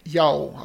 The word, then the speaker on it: Yeoh: I'm not pronouncing it correctly. She's in Crouching Tiger, Hidden Dragon. – Yeoh, I think it is pronounced. – Oh, my apologies. My Malaysian's terrible. – It's not Malaysian. It's Cantonese.